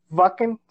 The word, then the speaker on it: Wacken: Wacken, si quieren el nombre este, bien pronunciado, en Wacken Open Air dentro de seis años.